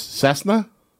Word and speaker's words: Cessna: How do you say this, Chris? Cessna? – Yes.